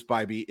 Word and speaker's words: Bibee: And by the way, we have confirmed it is Bibee. It is not Bibby, despite the fact that it is spelled like Bibby. B-I-B-E-E should absolutely be Bibby, in my personal opinion.